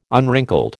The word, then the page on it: unwrinkled: American English